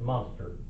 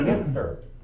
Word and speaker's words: Münster: It's called by most people that don't know how to pronounce it, Munster. It's actually Münster. It's a German word. All right, and this U should be translated like an I, or sounded out like an I, the Münster Rebellion.